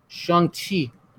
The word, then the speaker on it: Shang-Chi: You got to pronounce it correctly, man. There's a whole sequence in the movie where they talk about it's Shang-Chi.